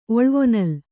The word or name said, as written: ಒಳ್ವೊನಲ್